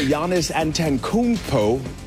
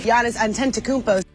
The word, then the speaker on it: Antetokounmpo: It's official NBA All-Star Giannis Antetokounmpo. I know I'm going to get work on that. – Giannis Antetokounmpo.